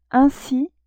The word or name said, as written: ainsi